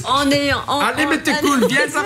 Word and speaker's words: Mette Koe: Et hop, Maître Cool, ça s'écrit M-E-T-T-E K-O-E. – Oui, K-O-E.